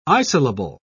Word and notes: isolable: British English

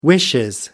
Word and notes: wishes: British English

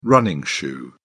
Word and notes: running shoe: British English

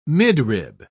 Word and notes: midrib: British English

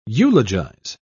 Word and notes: eulogise: British English

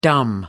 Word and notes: dumb: American English

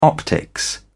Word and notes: optics: British English

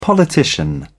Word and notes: politician: British English

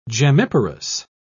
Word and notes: gemmiparous: British English

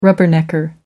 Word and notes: rubbernecker: American English

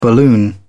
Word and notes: balloon: British English

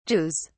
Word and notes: jews: British English

